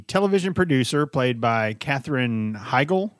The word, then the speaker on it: Heigl: Yeah, Heigl. She's not German. – It's H-E-I-G-L. – Heigl. There's no EL at the end. It's an IL or just a GL.